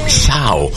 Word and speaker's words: Shaw: Se escribe S-H-A-W. Shaw. Se pronuncia Shaw.